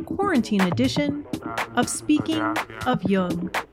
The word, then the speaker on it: Jung: You can also listen to this episode on your Amazon Echo device simply by saying Alexa, play Speaking of Jung on Apple Podcasts. Just be sure to pronounce Jung with a hard J.